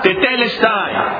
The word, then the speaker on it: tetelestai: Se pronuncia tetelestai.